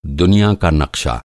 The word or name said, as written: नक़्शा